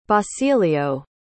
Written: basilio

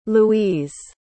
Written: louise

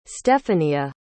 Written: steffania

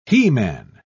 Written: he-man

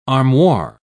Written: armoire

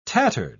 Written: tattered

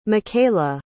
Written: micaela

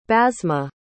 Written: basma

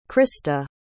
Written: christa

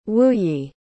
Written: wuyi

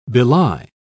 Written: belie

